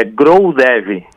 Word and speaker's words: Growdev: Perdoe se eu pronunciei errado, Grodev. É isso. Bem-vindo. Boa noite. – Boa noite. Boa noite aos ouvintes da Gaúcha. É Growdev, né? É um Growdev ali, né? Acentuei errado aqui, né?